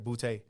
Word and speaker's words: Boutte: But I do know you got his last name right, Boutte.